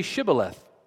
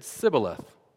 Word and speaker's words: Shibboleth: And when he said, "No," they said to him, "Then say Shibboleth." And he said, "Sibboleth," for he could not pronounce it right.